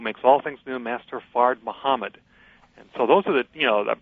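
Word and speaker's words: Fard Muhammad: The second thing is that the name Allah refers to all black men, all Muslims, but in particular refers to Master Farid Muhammad, or sometimes pronounced Farad Muhammad.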